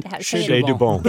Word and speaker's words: Chez du Bon: Oh, I'm going to say it like a redneck. Chez du Bon. What is it? – Chez du Bon. – There you go.